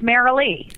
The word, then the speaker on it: Mary Lee: The emphasis is on the Lee.